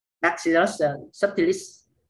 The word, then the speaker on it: Bacillus subtilis: Bacillus subtilis. Do I pronounce it right? – Yeah, so in the States they call it labs or lactobacillus. Yep, you pronounced it right.